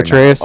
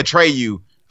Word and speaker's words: Atreus: I could I can't even pronounce his fucking name right. – Atreus. – Now. Atreus. Atreus. – Atreus. – I keep wanting to say Atreyu.